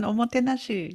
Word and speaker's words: omotenashi: And in Japan, I'm probably going to butcher this, they have this saying called omotenashi or something. – Got it. Yeah, yeah, that's the one, omotenashi.